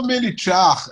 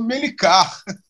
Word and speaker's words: Melichar: porque eu não sou Melichar, né? eu sou Melicar.